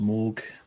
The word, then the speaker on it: Moog: It's pronounced Moog.